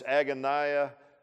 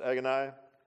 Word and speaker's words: agonia: That word agony there in the Greek is, it's agonia, I think is how it would be pronounced, agonia.